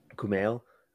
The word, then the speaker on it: Kumail: Kumail, I think. – Kumail, okay.